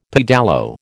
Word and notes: pedalo: American English

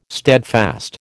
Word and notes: steadfast: American English

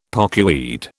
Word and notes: pokeweed: British English

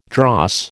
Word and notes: dross: American English

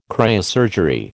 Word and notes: cryosurgery: American English

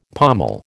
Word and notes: pommel: American English

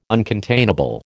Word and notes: uncontainable: American English

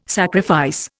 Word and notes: sacrifice: Indian

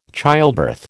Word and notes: childbirth: American English

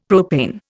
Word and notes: propane: Indian

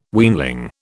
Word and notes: weanling: British English

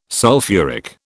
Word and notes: sulphuric: British English

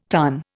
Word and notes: tun: Indian

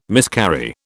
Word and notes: miscarry: British English

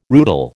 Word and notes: rootle: American English

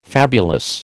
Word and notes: fabulous: American English

